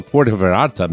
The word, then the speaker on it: Puerto Vallarta: Hey, guys, my girlfriend and I are traveling from Seattle to Puerto Vallarta, Mexico, this week to celebrate my 38th birthday.